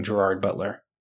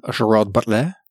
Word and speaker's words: Gerard Butler: We watched Hunter Killer, starring the amazing Gerard Butler. – You didn't pronounce that right. – Academy Award winning Gerard Butler? – Almost, but you didn't pronounce his name right. I think it's Gerard Butler.